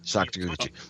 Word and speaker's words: Sakaguchi: Yeah, he actually lost the title to Seiji Sakaguchi. I hope I'm saying that right. – Sakaguchi.